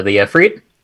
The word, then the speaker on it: Efreet: Frentic Efreet. Am I saying that right? – The Efreet? – Efreet.